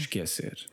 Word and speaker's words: esquecer: I would say get used to pronouncing it the way the Portuguese do. Yeah. Esquecer.